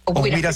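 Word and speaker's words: Ohuira: Dice, la planta se edifica directamente sobre esta bahía de Oguira, ¿se pronuncia? Ajá. Un humedal. Oguira, Sinaloa.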